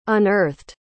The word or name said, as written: unearthed